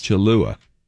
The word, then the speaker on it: Cholula: Cholula.